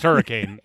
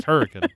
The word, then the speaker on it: Hurricane: It's not pronounced Hurricane. It's pronounced Hurricane.